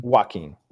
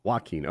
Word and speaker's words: Joaquin: Joaquin. – Joaquin.